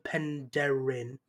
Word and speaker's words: Penderyn: Well, I'm having a Welsh whiskey, Welsh singing what was called Penderyn.